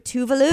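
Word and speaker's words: Tove Lo: Tovlo. – Yeah. – She just got tired of correcting everybody. She pronounces it Tuvalu.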